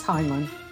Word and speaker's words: Heinlein: Yeah, so this week I thought we'd go over Robert Heinlein. Is that how you say it? – Yes, Heinlein.